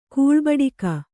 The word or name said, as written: ಕೂಳ್ಬಡಿಕ